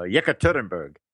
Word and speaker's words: Yekaterinburg: Yekaterinburg.